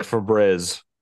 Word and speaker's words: Febreze: Like a breeze. It's one E. – It's Febreeze. – So like Febreze. Well, I guess the E on the end makes it a long E.